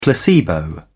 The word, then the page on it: placebo: British English